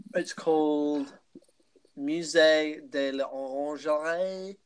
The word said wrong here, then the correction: l'Orangerie: Lingerie, like the Museum of Lingerie. Underwear Museum. – Because we can't go. – Yeah. – It's called the Museum of l'Orangerie.